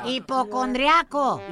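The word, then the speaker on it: hipocondríaco: Hipoconaco? ¿Cómo se dice? – Naco estás, güey. No. Hipocondriaco. – Hipocondraco. – Draco. Casi dice Drácula el compa.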